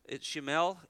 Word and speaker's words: Shemuel: But you'll notice in verse 20, I think most of our English translations spell it out Samuel, but it's Shemuel, I think is how it is pronounced in the Hebrew.